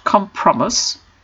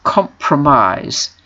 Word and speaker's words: compromise: A compromise, and note the pronunciation, it's not compromise, it's compromise, with a stress on the last syllable.